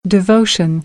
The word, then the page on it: devotion: American English; oʊ - əʊ